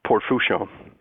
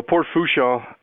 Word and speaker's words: Fourchon: Gulf of Mexico are serviced out of Port Fourchon.